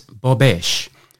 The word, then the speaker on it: bobeche: And this week's Word of the Week is Bobesh, and that is spelt B-O-B-E-C-H-E.